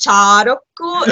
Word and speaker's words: Shārokku: Oh, well, you see, you have long vowels, so it's Sharoku, and then long consonants, and so on.